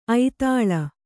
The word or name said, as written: ಐದಾಳಿ